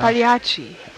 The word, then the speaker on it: Pagliacci: Pagliacci. – Really? When did he change his name? – That you don't pronounce the G in Italian. When it's in the middle of words like that.